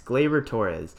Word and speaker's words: Gleyber: And, you know, Gleyber, I keep pronouncing his name wrong and it's throwing me off. It's Gleyber Torres.